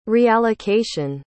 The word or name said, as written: reallocation